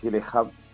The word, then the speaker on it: Gyllenhaal: Prisioneros es una película protagonizada por Hugh Jackman y Jack Gyllenhaal. Me parece que se dice así, no sé si... Hall. – Hall. – Sí, lo he dicho bien.